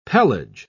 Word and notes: pelage: British English

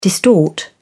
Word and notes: distort: British English

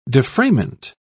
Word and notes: defrayment: British English

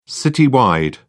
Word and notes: citywide: British English

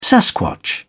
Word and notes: sasquatch: British English

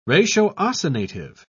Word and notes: ratiocinative: British English

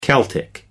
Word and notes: celtic: American English

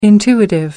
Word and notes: intuitive: American English

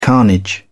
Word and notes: carnage: British English

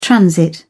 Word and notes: transit: British English

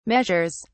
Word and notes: measures: British English

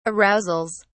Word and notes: arousals: British English

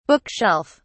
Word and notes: book shelf: British English

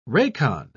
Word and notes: racon: British English

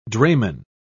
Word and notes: drayman: British English

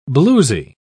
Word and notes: bluesy: British English

